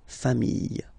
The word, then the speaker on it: familles: You just add this final S and you get the plural form, les familles, okay? Remember, chien, singular form, chien, same pronunciation, but you put this S, okay? Final S, not pronounced.